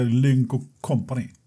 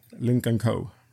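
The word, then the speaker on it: Lynk & Co: Ska, det, ska det uttalas så? Lincoln Co. Det Inte Lynk Company. Nej, Lincoln Co. heter det.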